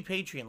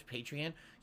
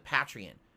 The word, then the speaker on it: Patreon: It should be Patreon, like you're a patron of the arts, Patreon. Yet fucking most people pronounce it Patreon.